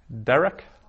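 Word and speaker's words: Derek (דֶּרֶךְ): Derek, another of those sigilate nouns, two sigils, accent on the first syllable.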